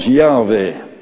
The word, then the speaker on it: Yahweh: Want het zijn eigenlijk vier, ja, medeklinkers, J-H-W-H. En dan, ja, hoe moet dat uitgesproken worden? Nou, waarschijnlijk als Yahweh.